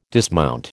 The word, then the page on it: dismount: American English